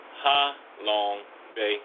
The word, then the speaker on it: Ha Long Bay: So actually it's pronounced Ha Long Bay. H-A-L-O-N-G.